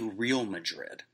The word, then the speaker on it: Real Madrid: Yeah, it's actually Real Madrid, I think is how you pronounce it.